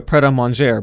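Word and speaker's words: Pret A Manger: a pret-a-manger by 2020. I pronounced that correctly.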